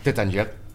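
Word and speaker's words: Taittinger: A ver, ¿cómo se pronuncia? – Tattinger. – Muy bien.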